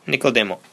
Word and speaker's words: Nicodemo: それは三章のネコデモ。 Probably pronouncing that wrong. Nicodemo, is it? Nicodemo. Okay. Nicodemo.